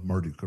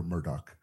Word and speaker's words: Marduk: But we have to understand his theological background, and Nebuchadnezzar he would have seen himself as the physical manifestation of Marduk or Murdoch, depending on how you pronounce it.